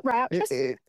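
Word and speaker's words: Ryaltris: Now, the drug or the medication that we're speaking about, you touched on earlier, Ryaltrix, is that how it's said? – Yeah, that's right. Yep, Ryaltrix.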